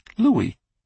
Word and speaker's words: Louis: Louis pronounced his first name Louis, or as he put it, the French way,